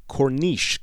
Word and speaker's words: Corniche: I believe it would be pronounced Corniche, because it's C-O-R-N-I-C-H-E, Corniche.